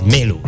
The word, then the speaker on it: Maelo: Yeah, Melo or Maelo. It's pronounced as Melo, though it's spelled as Maelo.